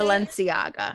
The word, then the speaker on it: Balenciaga: I know I'm probably going to say this wrong, but they're Balenciaga. Is that how you say Balenciaga. – It? Balenciaga. – Yeah.